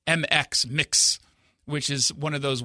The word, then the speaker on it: Mx: It's pronounced mix.